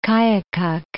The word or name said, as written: Koyukuk